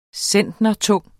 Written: centnertung

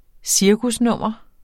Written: cirkusnummer

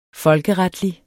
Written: folkeretlig

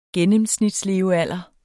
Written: gennemsnitslevealder

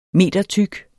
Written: metertyk

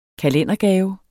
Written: kalendergave

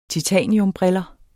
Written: titaniumbriller

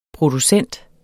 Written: producent

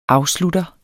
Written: afslutter

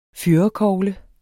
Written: fyrrekogle

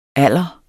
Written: alder

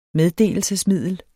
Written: meddelelsesmiddel

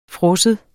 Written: fryser

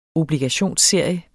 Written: obligationsserie

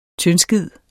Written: TYNDSKIDEN

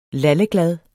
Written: LALLEGLAD

